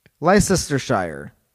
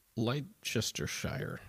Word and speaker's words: Leicestershire: Leicestershire. – Leicestershire, sure.